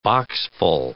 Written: boxful

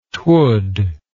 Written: twould

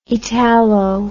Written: italo

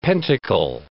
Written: pentacle

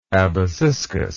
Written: abaciscus

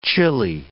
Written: chilli

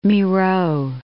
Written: Miro

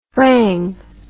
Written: thrang

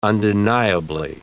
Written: undeniably

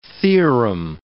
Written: theorem

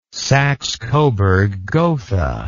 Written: saxecoburggotha

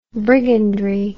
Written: brigandry

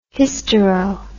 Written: hystero

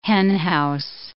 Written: henhouse

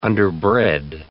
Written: underbred

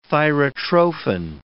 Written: thyrotrophin

